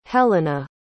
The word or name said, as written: helena